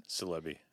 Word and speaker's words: Silebi: So my last name is Silebi, and there's no other meaning for salami other than it rhymes with my last name, although it's usually a good topic of conversation.